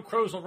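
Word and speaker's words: Krozel: Friday, July 10th by Joe Crozel rhymes with puzzle.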